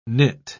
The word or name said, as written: nit